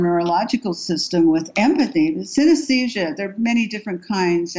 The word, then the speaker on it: synesthesia: synesthesia. – Yeah, synesthesia.